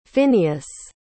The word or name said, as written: Phinnaeus